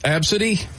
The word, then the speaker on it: Abcde: The first one was Absidy. – No. There'll be no Absidies. – Just spelled ABCDE, but you pronounced it Absidy.